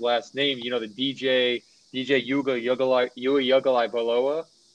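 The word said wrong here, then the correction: Uiagalelei: Yuga Lely.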